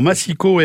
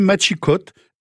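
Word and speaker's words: Machicot: Alors, Machico, M-A-C-I-C-O-T, Machico, M-A-C-I-Q-U-O-T, oui. – Et on trouve même Machicote, M-A-C-H-I-C-O-T-E, qui correspond à la prononciation espagnole et à celle ancienne à l'Ariège, justement.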